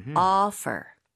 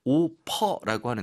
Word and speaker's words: offer: 네, F 발음 강하게 해 주시고 offer. offer. – 네, 우리가 이제 한국에서는 오퍼 들어왔다, 오퍼를 한다, 오퍼라고 하는데 그게 아니라 O 부분을 입 모양을 조금 더 벌리는 거. 그리고 당연히 F라고 하는 것은 입술이 붙으면 안 됩니다. – 네. – 그래서 offer, 오퍼가 아니라 offer. Yes. 자, 다음은요, 짐. – luggage. 스펠링 주의하세요. L-U-G-G-A-G-E.